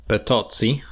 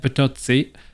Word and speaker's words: Bertozzi: How do you say it in Italian? Come on. – Oh, how is it written? – B-E-R-T-O-Z-Z-I. – Potosi. – Yeah, Potosi.